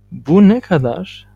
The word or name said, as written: bu ne kadar